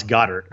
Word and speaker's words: Goedert: Is it Goedert or Goedert? – I say Dallas Goddard.